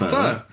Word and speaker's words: pho: Yeah, it's called pho. – Pho. – Fur. Okay. Yeah. Fur. I didn't know that bit. I just called it fur. – Yeah. I mean, at least you somewhat said it right.